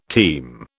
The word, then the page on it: team: British English